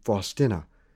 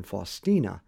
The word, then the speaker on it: Faustina: As you notice, I pronounce her name Faustina rather than Faustina, because a former student of mine, God rest his soul, who was from Poland, politely reprimanded me for pronouncing it incorrectly.